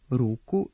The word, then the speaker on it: ruku: Kako se slog ispred ovog nastavka u genitivu množine po pravilu ne duži, prema Stevanovićevoj gramatici i rečniku srpskog jezika Matice srpske, ove oblike trebalo bi izgovarati sa kratkouzlaznim akcentom, dakle, mnogo ruku, nogu, slugu, uz dozvoljen dubletni oblik sluga sa dugouzlaznim akcentom.